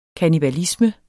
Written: kannibalisme

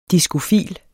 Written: diskofil

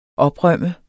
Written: oprømt

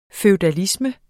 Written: FEUDALISMEN

